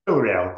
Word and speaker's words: Villarreal: Villarreal, close enough.